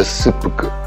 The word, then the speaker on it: seppuku: I samurai appunto, qui vedete un estratto, la scena dell'Arakiri, Arakiri per l'appunto, non Karakiri, che poi sarebbe ancora meglio definire seppuku, che si pronuncia seppuku, che è il vero termine del suicidio rituale per onore dei samurai.